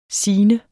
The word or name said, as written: signe